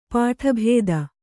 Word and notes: ಪುಟಭೇದ: puṭa bhēda

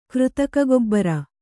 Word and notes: ಕೃತಕಗೊಬ್ಬರ: křtaka gobbara

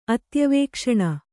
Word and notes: ಅತ್ಯವೇಕ್ಷಣ: Kannada

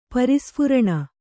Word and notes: ಪರಿಸ್ಫುರಣ: Kannada

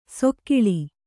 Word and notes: ಸೊಕ್ಕಿಳಿ: sokkiḷi

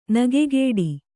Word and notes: ನಗೆಗೇಡಿ: Kannada

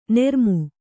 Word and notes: ನೂರ್ಮೆ: nūrme

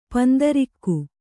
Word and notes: ಪಿಂತಿರುಗು: pintirugu

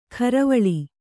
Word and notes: ಕರವಳಿ: karavaḷi

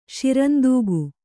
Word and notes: ಶಿರಂದೂಗು: kannada

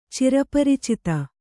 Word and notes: ಚಿರಪರಿಚಿತ: cira paricita

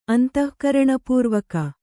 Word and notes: ಅಂತಃಕರಣಪೂರ್ವಕ: kannada

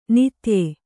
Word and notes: ನಿತ್ಯೆ: nitye

